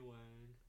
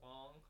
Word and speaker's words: Wang: Then we met Tony Wang, the man, the myth, the legend. – I think it's pronounced Wong. – It is pronounced Wong, but if he says that to other people, they spell it W-O-N-G.